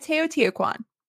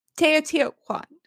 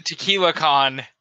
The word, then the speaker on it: Teotihuacan: This bad boy, it predates the Roman Empire, Jesus Christ himself, the Olympics, and even the Mexican pyramid at Teotihuacan. Teotihuacan. – Tequila-con.